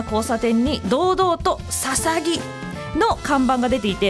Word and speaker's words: 大角豆: 大きな角の豆と書くので、初見では大角図や大角豆、大津の豆としか読めないのですが、なんとこれでささぎと読むのです。